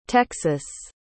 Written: texas

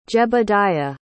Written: jebediah

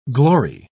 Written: glory

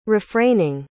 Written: refraining

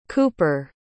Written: kooper

